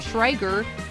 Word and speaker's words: Schrager: Schrager.